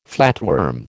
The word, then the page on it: flatworm: American English